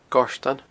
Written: Goirtean

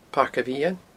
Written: Pairc a' Mheadhain

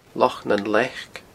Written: Loch nan Leac

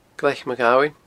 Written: Glaic Maghabhain

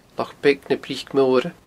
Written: Loch Beag na Bric Moire

